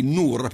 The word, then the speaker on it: Nur: Si chiamano Nur, N-U-R col punto finale. Quindi Nur, penso sia la pronuncia.